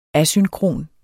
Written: asynkron

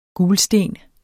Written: gulsten

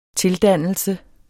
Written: tildannelse

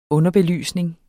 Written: underbelysning

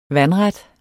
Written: vandret

